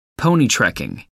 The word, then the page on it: pony-trekking: American English